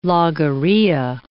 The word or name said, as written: logorrhea